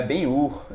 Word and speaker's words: Ben-Hur: aí eu, eu disse, ele disse, não, é bem Ur, não é com R não.